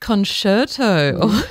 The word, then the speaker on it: concerto: The flute concerto, did I say that correctly? – You did.